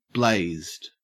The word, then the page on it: blazed: Australian English